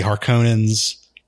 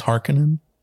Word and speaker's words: Harkonnen: Since the Harkonnens outnumber the Atreides. – It's Harkonnen? – Harkonnen, excuse me.